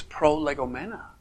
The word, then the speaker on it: prolegomena: And if you want to pronounce the Greek, it's prolegomena. But when you're a theologian making up big words, you say prolegomena.